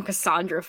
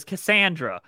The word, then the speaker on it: Cassandra: Also, by episode 6, we finally agreed how to say her name. So thank Cassandra? – God. Yeah, I think they agreed on Cassandra finally. – Yes, but Eugene still says Cassandra because they recorded Zachary Levi in one day and one take for every line.